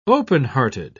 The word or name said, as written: openhearted